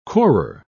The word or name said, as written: corer